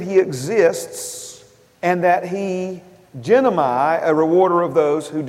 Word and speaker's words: ginomai: This word, the transliteration of it is genomai, G-I-N-O-M-A-I, if I'm pronouncing that correctly.